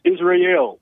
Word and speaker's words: Israel: Israel, am I pronouncing your name correctly? – Nope, it's Israel.